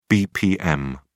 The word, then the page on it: bpm: British English